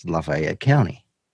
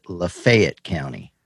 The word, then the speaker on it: Lafayette County: And so you may think that it is Lafayette County, right? But no, in Mississippi, they pronounce it Lafayette County.